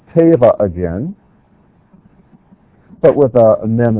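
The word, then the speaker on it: teva (טבע): The first one is teva.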